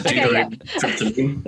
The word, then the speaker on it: deuterated tryptamine: So look, first of all, did I say it right? – Yeah, you did. It's a deuterated tryptamine. That's right.